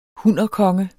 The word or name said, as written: hunnerkonge